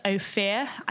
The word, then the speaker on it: Ophir: But one of our favourite is Ophir. I think that's how you pronounce it. It's O-P-H-I-R. – That's how I pronounce it. We'll say that's right for now.